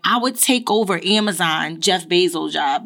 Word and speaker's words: Jeff Bezos: Jeff Bezos, move the fuck over.